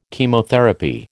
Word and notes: Chemotherapy: American English

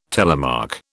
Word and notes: telemark: British English

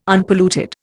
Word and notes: Unpolluted: Indian

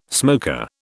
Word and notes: smoker: British English

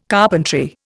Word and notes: carpentry: Indian

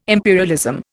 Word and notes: imperialism: Indian

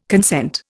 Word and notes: consent: Indian

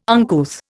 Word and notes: uncouth: Indian

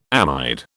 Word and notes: amide: British English

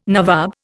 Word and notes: nawab: Indian

